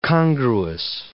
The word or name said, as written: congruous